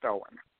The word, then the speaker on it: Eoghan: Yeah, so for any of those who maybe have seen it on social media or anything, the name is it's spelled E-O-G-H-A-N, which is the Gaelic spelling for Owen. So it's pronounced Owen.